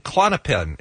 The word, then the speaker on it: Klonopin: Well, he's in the news right now because he's been arrested and they have seized crack cocaine, fentanyl, Percocet, Suboxone, and Klonopin.